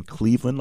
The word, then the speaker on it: Cleveland: My first letter of my first name is J, and then Cleveland, like the city, and my last name is spelled P-A-Y-N-E.